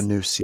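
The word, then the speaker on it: nous: The noose, yes.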